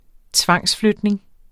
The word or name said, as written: tvangsflytning